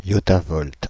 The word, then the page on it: yottavolt: France (Île-de-France); [yɔ.ta.vɔlt]; /jɔ.ta.vɔlt/